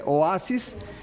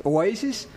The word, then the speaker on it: Oasis: Hay gente que le dice Oasis. Pues lamento mucho su pronunciación, es Oasis.